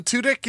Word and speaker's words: Tudyk: Alan Tudyk is the...